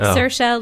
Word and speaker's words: Saoirse: and it's like Saoirse, like inertia.